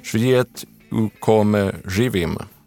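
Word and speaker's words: Svijet u kome živim: Den här är från 1971, och alltså, nu ber jag om ursäkt för min serbokroatiska, men jag tror att den heter Svijet ukome živim. – Ja, mitt tränade öra säger att du nailade det där uttalet.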